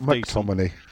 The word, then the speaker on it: McTominay: I thought it was Scott McTominay. – Oh, yeah. I knew you were going to pick me up in the pronunciation.